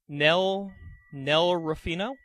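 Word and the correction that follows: Nei Ruffino: Oh, I think that's an I. Nay Ruffino? – Nay Ruffino? Okay.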